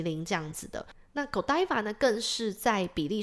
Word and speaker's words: Godiva: 好，那接下来另外一个比利时还有一个知名的品牌叫做 Godiva，对，有人叫 Godiva，美国发音啦，那比利时当地的发音是 Godiva。